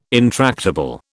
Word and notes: intractable: British English

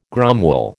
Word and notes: gromwell: American English

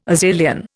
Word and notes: Azilian: Indian

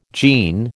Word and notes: jean: American English

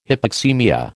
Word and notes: hypoxaemia: American English

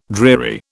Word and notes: dreary: British English

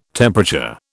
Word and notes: temperature: British English

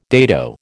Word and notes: dado: American English

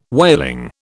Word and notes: whaling: British English

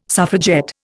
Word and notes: suffragette: Indian

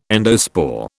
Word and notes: endospore: British English

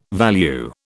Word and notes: value: British English

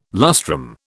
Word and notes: lustrum: British English

